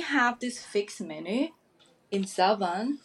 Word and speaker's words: Zawan: Am I pronouncing that right? – Yeah. – Zawan.